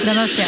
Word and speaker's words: Donostia: Donostia. – Donostia. – Gosh. – Donostia.